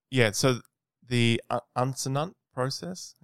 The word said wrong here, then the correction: uncinate: I can't pronounce it. UNC. How do you pronounce it? – I don't know. You gotta spell it for me. – UNCINATE. – Uncinate. – Uncinate. – Yeah.